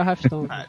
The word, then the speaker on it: Hearthstone: Eu joguei Diablo 2, joguei Warcraft, joguei joguei o WoW, é, jogo Hearthstone, eu não sei como é que pronuncia isso direito. É o Hearthstone.